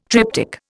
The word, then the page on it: triptyque: Indian